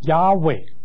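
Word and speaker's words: Yahweh: 再有这边讲到哈利路亚，这个亚呢就是耶和华的简称，耶和华他原来的那个发音呢是亚伟，这个亚呢就是这个亚伟的第一个音节，只用一个音节，就是取它的音比较短节洪亮，比较简短有力，所以讲到说哈利路亚。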